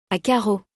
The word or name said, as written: à carreaux